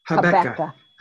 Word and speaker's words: Habakkuk: I've always said Habakkuk, but it's Habakkuk? – Habakkuk.